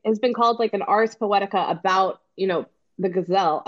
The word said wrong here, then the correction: ghazal: A gazelle. No, it's the first one to say it wrong. No, I knew I'd say it wrong at some point. I'm sorry, I was the first one. But anyway, an ars poetica about the ghazal form,